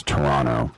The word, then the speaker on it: Toronto: And by the way, I do realize that people in Ontario don't pronounce the last T, that it's Toronto.